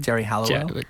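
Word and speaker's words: Geri Halliwell: Jerry Halliwell.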